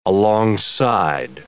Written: alongside

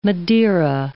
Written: Madeira

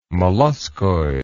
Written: molluscoid

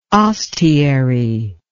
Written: ostiary

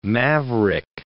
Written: maverick

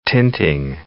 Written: tinting

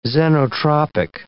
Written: xenotropic

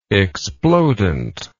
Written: explodent

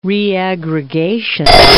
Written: reaggregation

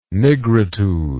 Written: nigritude

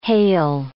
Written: hale

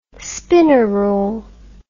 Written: spinnerule

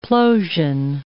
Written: plosion

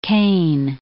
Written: Kane

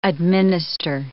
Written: administer

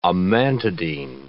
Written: amantadine

